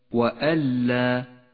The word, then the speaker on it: وألا: وأن لا النون الساكنة وبعدها حرف اللام إدغام بلا غنة تسقط النون من اللفظ وتشدد اللام التي بعدها ولذلك لونت النون بالرمادي إشارة إلى عدم نطقها